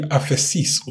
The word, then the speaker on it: aphesis: The word is actually aphesis, okay? Dismissal. Look at this. It means, and it's, look at the pronunciation, aphe, okay? Aphesis, okay?